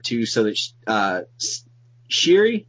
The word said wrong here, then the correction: Shuri: is that how you say her name? – Shuri, yeah. – Shuri, yeah.